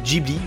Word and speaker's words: Ghibli: Et oui, on dit Ghibli, je me répète encore, hein, c'est même Sutajiyo Ghibli si on prend euh, la prononciation à la japonaise, donc pas Ghibli, jamais de la vie.